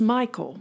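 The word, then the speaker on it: Mikael: it's M-I-K-A-E-L. – Right. – But it's Michael.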